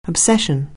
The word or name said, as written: obsession